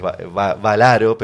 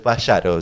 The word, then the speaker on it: Vallaro: muchas veces lo pronuncian a, mi apellido que es Valaro, pero es Vallaro. Llegué a corroborar que es Vallaro porque es ah.